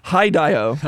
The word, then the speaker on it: Hideo: Can you say his name? – Hideo. – Okay. That's perfect.